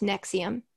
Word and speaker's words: NXIVM: So if you need a Wikipedia wormhole to go down, might I suggest Nexium, which is spelled NXIVM.